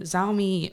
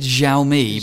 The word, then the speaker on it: Xiaomi: So moving on, we then have another story related to the Xiaomi major camera data exposure. Did I say that right? – I would have said Xiaomi, but that was technically, okay. Yeah, but no, you did.